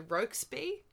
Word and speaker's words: Rokesby: So, shall I ask you, would you recommend the Rokesby? Is that right? I said it wrong the first time, so now I'm going to trust myself. – Yeah, no, that's fine.